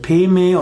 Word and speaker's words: Padme: Da wird eben aus Padme wird Peme und aus Hum wird Hung.